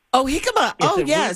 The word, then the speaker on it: jicama: Yes, and so I have to tell everybody, you spell that J-I-C-A-M-A. – Yes, it starts with a J. – So you say jicama.